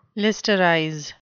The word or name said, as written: listerize